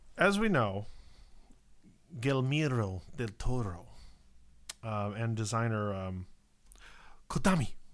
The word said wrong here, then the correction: Hideo Kojima: Hideo Kojima.